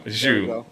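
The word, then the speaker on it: Zhou: I always make you mispronounce his name. Zhao? – Oh, Zhao. – Yeah, points in his first race. – Guan Yu Zhu. There you go, man. – Yeah, Zhu. There it is.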